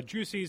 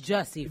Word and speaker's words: Jussie: Juicy Smollett. Is it Juicy? How do you say it? – It's Jussie, for Jussie.